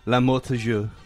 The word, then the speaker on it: L'Amour Toujours: A moment of silence and appreciation for L'Amour Toujours.